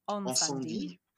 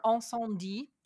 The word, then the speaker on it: Incendies: Incendies. Yeah. – Incendies.